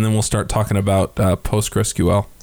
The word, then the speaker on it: PostgreSQL: The correct pronunciation is actually PostgreSQL. Okay. But almost nobody guesses that off the bat, which is why most people say Postgres.